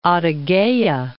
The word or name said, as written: Adygeya